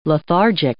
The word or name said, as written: lethargic